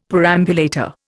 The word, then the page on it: perambulator: Indian